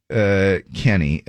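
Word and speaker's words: Khennee: My name is Kenny, but I spell it with a K-H-E-N-N-E-E. the H is silent, and I don't know.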